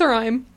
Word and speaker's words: Sualdaim: So it's pronounced Sarim. It's spelled S-U-A-L-D-A-I-M. That doesn't make any sense. – There's a D in there and an L. – Yeah. We don't say the D. Don't say the L. Don't say the A. – Okay. – Either A. There's two of them. Neither of them are pronounced.